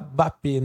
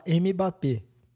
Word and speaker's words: Mbappé: Aliás, só um adendo aqui, você fala Mbappé, né? E eu falo Mbappé, tipo, eu acho que o certo é não falar, né, o M. – O M é mudo.